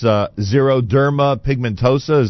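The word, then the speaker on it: xeroderma pigmentosum: I explained, or at least I tried to pronounce, xeroderma pigmentosa.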